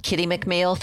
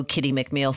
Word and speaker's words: Kitti McMeel: Well, on Instagram, Kitti McMeel Photography. On Facebook, also Kitti McMeel Photography.